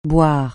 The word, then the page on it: boire: francais